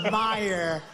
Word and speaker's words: Meijer: A guy who spells his name M-E-I-J-E-R, but they pronounce it Meyer.